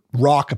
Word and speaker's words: Roch: So episode, we covered a bit of like Rock apparently is more how you pronounce it, but I'm going to stick with Roche because that's how I've been pronouncing it.